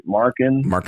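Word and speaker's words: Markkanen: Is it Markin? – Markin. – Yep, that. Mm-hmm. I said it right for everybody listening.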